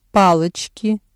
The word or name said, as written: палочки